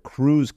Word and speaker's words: krewe: And that's where the crews came into place, right? And by crew, it's spelled K-R-E-W-E, but there are a whole bunch of organizations that throw on these parades, right?